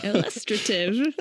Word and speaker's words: illustrative: illustrative of the illustrative. That's how you pronounce it.